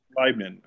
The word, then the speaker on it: Leibniz: In your second book, chapter two, The Enlightenment, from Bacon and Flood to Newton and Leibniz, I think I'm pronouncing Leibniz. – That correct. – Leibniz.